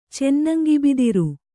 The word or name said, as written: ಚೆನ್ನಂಗಿಬಿದಿರು